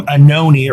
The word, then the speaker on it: Anoni: Last name Anoni rhymes with baloney. – Say it again. – Last name Anoni.